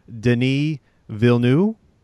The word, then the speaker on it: Denis Villeneuve: All right, my number six is Arrival, which I finally figured out the way to pronounce the director's name. I believe the correct pronunciation is Denis Villeneuve, I believe is how you say it.